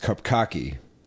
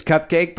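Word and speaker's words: Cupcakke: It looks like it's pronounced Cupcocky. – It does kind of look like Cupcocky. – So I'm thinking it's Cupcake, but it does look like Cupcocky. It's C-U-P-C-A-K-K-E.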